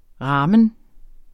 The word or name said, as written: ramen